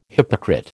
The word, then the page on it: hypocrite: American English